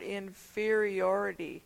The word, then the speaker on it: inferiority: Okay, as far as the word inferiority, the second syllable, in fear. Mm-hmm. Fear is what you get when you are inferior.